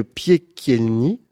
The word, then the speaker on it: Piekielny: Monsieur Piekielny, c'est ça ? – Oui, c'est ça ? – On le prononce comme ça.